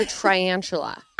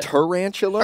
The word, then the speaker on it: tarantula: Tarantula? I love tarantulas. – How do you say it? What? Tarantula or triantula? Or Is it a tarantula? – Or a tarantula. – It's tarantula, not triantula.